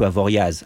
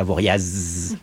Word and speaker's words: Avoriaz: And we have Anthony, and we have Romain Métanier, originally from Metz, you know, it's like when I go to Avoriaz, it sounds like a tourist when I say Avoriaz. sometimes in French, there are some, like, silent letter at the end of a town's name, and we're not supposed to pronounce it. And when you're a tourist, like I am, because I'm a Parisian, often you show up and you say the silent letter and they can spot you right away.